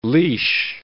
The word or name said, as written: leash